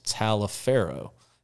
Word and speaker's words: Taliaferro: Um, I grew up, uh, one of the counties that, that was neighboring to us was what we called Taliaferro County but it was spelled the same way as the turfgrass breeder, which is Dr. Charles Taliaferro, is how he says it.